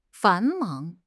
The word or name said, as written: 繁忙